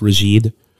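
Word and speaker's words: Rasjid: But Carrie was not really into music, and Sufjan's father, that I believe his name is pronounced Rajid, they didn't have like a stereo or anything in the home, but Lowell would send Sufjan Leonard Cohen tapes.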